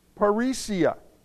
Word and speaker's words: parrhesia: What I want to share with you today and really get into and work as workmen of God's Word is a Greek word, parrhesia. And it's spelled P-A-R-R-H-E-S-I-A. Now, I'm not a Greek scholar. I might be mispronouncing this, but that's the spelling of this word, parrhesia, and it's translated in different English words, many times bold.